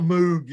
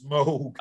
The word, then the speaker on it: Moog: I was buying all of his albums on 8-track, but, he made me, you know, everybody's heard of the Moog synthesizer, but screw that. I wanted the ARP synthesizer. – It's Moog. – Yeah. I don't give a fuck. – It's not Moog. It's Moog.